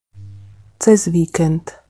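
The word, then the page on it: cez vikend: Slovak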